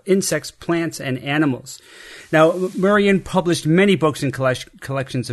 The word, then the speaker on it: Merian: For this week's Forgotten Superheroes of Science, I'm covering Maria Sibylla Marion, M-E-R-I-A-N. How would you pronounce that? – Marion. – I'm leaning towards Marion.